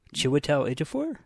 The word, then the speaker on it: Chiwetel Ejiofor: Chiwetel Ejiofor? – There it is.